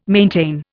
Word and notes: maintain: Indian

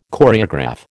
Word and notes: Choreograph: American English

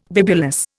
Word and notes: bibulous: Indian

